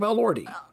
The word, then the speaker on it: Elordi: And that's how you say it, Jacob Elordi.